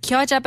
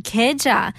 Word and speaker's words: 겨자: And and mustard, kyoja, but pronounced keja. Uh, so I guess uh, cho kie Tang. Uh, with the or with the regional accent, they would say kieja instead of kyoja.